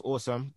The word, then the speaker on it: Osome: I think it's meant to be pronounced Awesome, but it's pronounced O-S-O-M-E. – It's spelled O-S-O-M-E, not it's pronounced O-S.